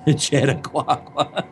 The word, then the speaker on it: Chautauqua: Oh, dear God, how do you pronounce that? It starts with a C. C-H-A-U-T-A-U-Q-U-A. – Chautauqua? – Is that how it's pronounced? Chautauqua? – Chautauqua. – I don't know when there's that many vowels together. – I think it's just Chautauqua.